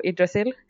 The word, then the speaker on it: Yggdrasil: And I'm going to be annoying and pronounce it in Swedish, all the Norse names now. But anyway, Yggdrasil is from Norse mythology and is the world tree.